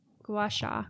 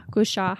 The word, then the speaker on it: gua sha: Gua sha. Gua sha. How do you say that? – I say gua sha.